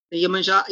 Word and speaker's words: Iemanjá: Iemanjá is one of the Orishas. That's how it's said in Yorumba.